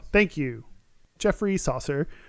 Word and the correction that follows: Chaucer: Chaucer. It's Chaucer.